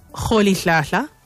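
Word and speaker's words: Rolihlahla: My most favorite fact about Tata Madiba is that his name, his birth name, which is, as Tariq pointed out, the correct pronunciation, Kholi Hlahla, commonly written as Roli Hlahla, Kholi Hlahla, that it means troublemaker.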